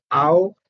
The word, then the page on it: au: Sanskrit